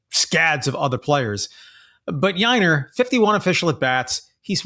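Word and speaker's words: Yainer: Yiner rhymes with minor, as in leagues.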